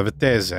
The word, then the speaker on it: Vettese: I actually had talked to a friend of mine who's Italian. I was like, how would you pronounce this name? And he's like, oh, that's Vettese.